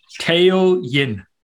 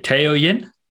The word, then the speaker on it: Tao Yin: So it's spelled T-A-O. And yep. Yin is as in Y-I-N, right?